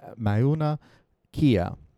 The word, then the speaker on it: Mauna Kea: So, um, but I think it's actually pronounced uh, Ma- Mauna Kea.